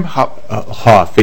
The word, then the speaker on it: Hoff: Mr. How do you say your name? – Hoff, H-O-F-F.